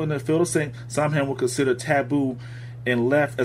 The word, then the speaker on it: Samhain: Spelled Sam like Sam Hain, but it's pronounced Samhain.